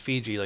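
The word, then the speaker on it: Fidji: I would love to have the new Instacart CEO, Fiji. I guess I found out her name is pronounced Fiji, like the Fiji Islands.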